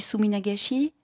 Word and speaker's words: suminagashi: Voilà, il va y avoir du suminagashi. – Ça se prononce comme ça, s'éternue à peu près.